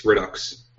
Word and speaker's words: Redux: Also, you did pronounce it wrong. It's Redux.